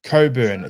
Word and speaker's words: Cockburn: C-O-C-K-B-U-R-N. Coburn, it's pronounced, but Coburn, on the back of his shirt is Cockburn.